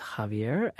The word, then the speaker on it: Javier: I mispronounced Attorney General Becerra's first name a minute or two ago. It's Javier.